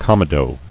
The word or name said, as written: Comedones